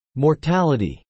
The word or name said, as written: mortality